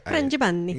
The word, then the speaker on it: Frangipani: The main player is Frangipani. I think that's how you say it. – Frangipani. – Yeah, there you go.